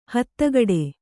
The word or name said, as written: ಹತ್ತಗಡೆ